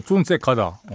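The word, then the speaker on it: Jon Secada: 그래서 처음에 존 세카다 나왔을 때 존 세다카라고 발음하는 음, 잘못 발음하는 네, DJ도 있었다는 네, 전설. 저는 아닙니다. 저 미리 얘기했는데 저는 세카다 처음부터 어, 쉽더라고요. 존 세카다.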